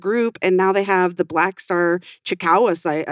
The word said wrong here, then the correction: Chiricahua: I think it's how you say it, Chikawa. But they have these locations, Chiricahua. – Chiricahua that's what it's called.